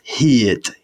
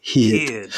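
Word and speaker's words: hud: But instead of hid and making it like a really long word, we then have the letter U, which is pronounced in Welsh as E. So it's almost like an E sound. So it's hid, hid.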